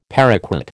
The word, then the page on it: paraquat: American English